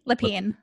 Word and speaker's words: Lepine: Lepine.